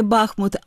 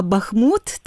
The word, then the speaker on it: Бахмут: Спершу ми запам'ятаємо разом з нашими слухачами, що правильно говорити не Бахмут, а Бахмут, так?